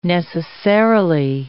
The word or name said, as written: necessarily